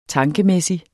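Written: TANKEMÆSSIGT

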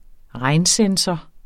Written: regnsensor

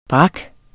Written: brak

